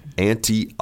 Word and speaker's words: ante: You usually have to, not all games, but you often have to ante up. – Right. So it's not like your mother's sister. – Your auntie. – Same pronunciation, but it is A-N-T-E.